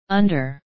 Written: under

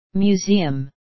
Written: museum

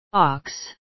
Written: ox

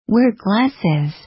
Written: wear glasses

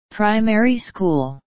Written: primary school

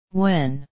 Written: when

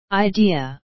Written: idea

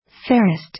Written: fairest

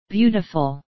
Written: beautiful

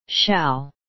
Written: shall

